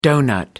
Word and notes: doughnut: American English

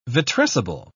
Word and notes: vitrescible: British English

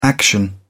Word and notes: action: British English

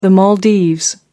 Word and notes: Maldives: American English

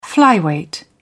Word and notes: flyweight: British English

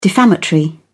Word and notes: defamatory: British English

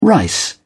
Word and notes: rice: British English

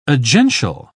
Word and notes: agential: British English

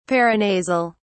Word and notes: paranasal: British English

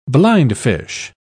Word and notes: blindfish: British English

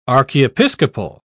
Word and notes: archiepiscopal: British English

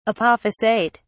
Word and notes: apophysate: British English

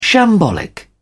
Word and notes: shambolic: British English

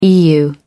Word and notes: eu: American English

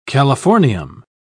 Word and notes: californium: British English

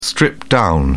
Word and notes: stripped-down: British English

